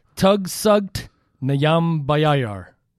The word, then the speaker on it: Tugstsogt Nyambayar: It's Tugstsogt Nyambayar.